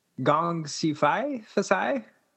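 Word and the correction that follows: Gongxi Fa Cai (恭喜发财): It's a Gongxi Fa Cai.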